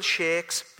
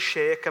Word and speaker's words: sheikh: Now, that word sheikh can be pronounced sheikh or sheik, okay? So just in case you think I sounding a bit daft, I have checked it out, okay?